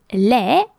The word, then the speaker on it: laie: But there is also another noun that is sounded lait, but which is feminine. And it is spelled L A I E,